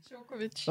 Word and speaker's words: Djokovic: Og til sidst, men ikke mindst, tak fordi du kom med at sige Kukovic. Og nej, udtalte jeg det igen forkert. Ret mig lige. – Djokovic.